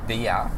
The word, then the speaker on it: Bea: It's pronounced Bea, but it's B-E-A.